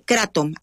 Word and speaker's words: kratom: Es kratom, así se llama. Kratom en inglés se pronunciará kratom.